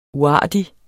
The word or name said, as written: uartig